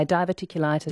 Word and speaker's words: diverticulitis: Di in Mandurah says, what about diverticulitis? Is that, I haven't pronounced that correctly. – Yeah, diverticulitis.